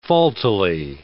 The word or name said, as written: faultily